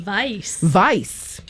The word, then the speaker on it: Weiss: Weiss. Proper German pronunciation.